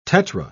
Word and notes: tetra: British English

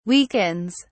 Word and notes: weakens: British English

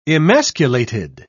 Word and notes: emasculated: British English

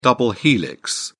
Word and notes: double helix: British English

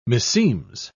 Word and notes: meseems: British English

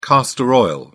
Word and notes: castor oil: British English

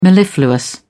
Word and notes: mellifluous: British English

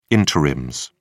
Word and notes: interims: British English

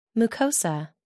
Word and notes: mucosa: American English

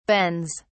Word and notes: bens: British English

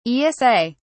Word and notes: esa: British English

